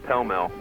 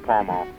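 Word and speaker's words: Pall Mall: I called to tell you guys that it's pronounced Pell-Mell, not Pall-Mall.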